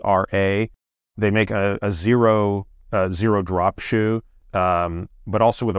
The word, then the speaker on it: Xero: I'm partial to a brand called Zero, which is spelled X-E-R-O.